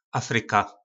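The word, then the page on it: afryka: nářečí Po našimu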